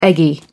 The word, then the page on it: eggy: American English